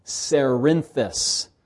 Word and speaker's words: Cerinthus: And then there was another fellow named Cerinthus. And it's spelled just like Corinth, but Cerinth. So it's got an E, not related to Corinth.